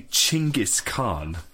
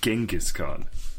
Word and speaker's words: Chinggis Khan: As well, so I work with a Mongolian, and She actually told me that we've been pronouncing Genghis Khan wrong our entire lives. It's actually Chinggis Khan, not Genghis Khan.